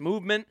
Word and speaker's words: MVMT: I love this sponsor because Movement Watches, MVMT Watches. That's pronounced Movement, founded on the belief that style shouldn't break the bank.